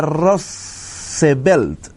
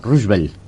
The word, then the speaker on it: Roosevelt: Roosevelt. Estados Unidos vivía la crisis del 29. – Roosevelt, no, Roosevelt.